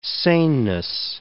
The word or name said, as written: saneness